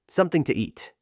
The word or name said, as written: Something to eat